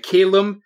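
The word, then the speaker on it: Caelum: Calum Vatsindal.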